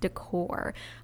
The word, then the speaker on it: Decours: And you'll have to forgive my rudimentary French, but I think it is pronounced decor, but spelled D-E-C-O-U-R-S.